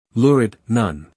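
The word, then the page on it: lurid: uk ˈlʊərɪd; en-us ˈlʊrɪd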